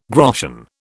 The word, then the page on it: groschen: British English